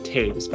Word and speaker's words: Toews: Her last name is pronounced Toews, by the way.